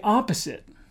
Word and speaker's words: opposite: Pronunciation tip, remember, the word opposite, the first syllable is stressed.